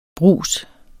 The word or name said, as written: brus